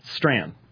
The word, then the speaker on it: Strachan: This popped up from, now, I've been told Owen, STRACHAN, Chris, wrote to me, Chris Arnzen wrote to me, and said that he's had him on the program, and that he pronounced that strand. To which I responded that he needs to spell it, STRAN. Because I'm sorry, but especially a vowel and CH together, ignoring them is an insult to the written form of language.